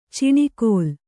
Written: ಚಿಣಿಕೋಲು

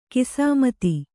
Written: ಗೋಸಮಿತಿ

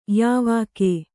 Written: ಯಾವಾಕೆ

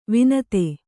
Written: ವನಧಿ

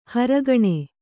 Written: ಹೊರಗಣ

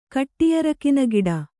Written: ಕಟ್ಟಿಯರಕಿನಗಿಡ

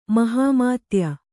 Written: ಮಹಮ್ಮದೀಯ

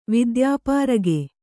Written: ವಿದ್ಯಾಪಾರಗೆ

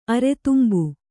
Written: ಅರೆತುಂಬು

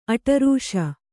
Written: ಅಡರಾಸು

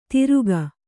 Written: ತಿರುಕ

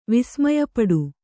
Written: ವಿಸ್ಮಯಪಡು

